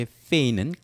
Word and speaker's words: feign: 이렇게 feign은 가짜 행동을 말합니다. 그런데 어원이 생각이 안 난다고 그러면 여러분께서는 여기서는 G는 묵음이에요. feign이라고 있는데 이것은 대원군을 연상해서 대원군이 자기 아들을 왕으로 만들기 위해서 고종이 왕이 되게 하려고 어떻게 했어요?